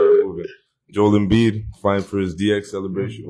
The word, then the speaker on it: Embiid: First of all, bro. – It's Embiid.